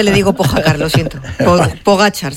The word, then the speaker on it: Pogacar: Un amigo nos dice también por WhatsApp que pronunciéis por favor bien el nombre de Pogacar. – Pogacar, ya. Es verdad, Pogacar.